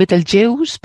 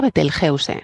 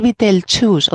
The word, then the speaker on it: Betelgeuse: En inglés se llaman Betelgeuse, pero es porque el juice ese como deuce, como juice, le suena que se pronuncia así. En francés, Betelgeuse. Y en alemán lo llaman Betelgeuse, ¿no? Y nosotros, según la Fundeu, Fundeu RAE, Dice que se pronuncia Betelgeuse, como suena, y que decir Betelchus o Betelgeuse es pronunciarlo a la inglesa y no tenemos por qué, porque tampoco no es una palabra inglesa, como has dicho muy bien, es árabe.